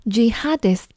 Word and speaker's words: jihadist: 接下来这个词看起来有点奇怪，它读作 jihadist，圣战主义者。